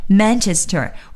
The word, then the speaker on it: Manchester: 个发音和中文的意词啊，有的时候非常的接近，比如说 Manchester 就是曼彻斯特，但是同时呢，我们也一定要注意，在我们说这个英文词的时候呢，千万不要带上中文的调，也就别说曼彻斯特，这是不行的，我们要说 Manchester。